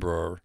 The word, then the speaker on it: Brer: He changed the spelling of his first name to Brewer, the pronunciation of which was similar to his Swedish name Brer.